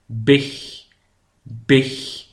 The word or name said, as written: de big